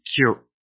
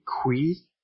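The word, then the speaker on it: kyu: It is not, as I have heard sometimes, some people might say Kui or Kaiyu. Kaiyu is a character, cartoon character on television in Canada. But it is neither Kaiyu nor is it Kui. It is Q-K-Y-U, using the Japanese pronunciation rules.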